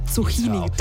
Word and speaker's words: Zucchini: Also spricht man das mit K oder mit Zucchini oder Zucchini? – Ja, mit K, Zucchini. – Weil ich sag dir ganz ehrlich, ich habe ganz lange Zucchini.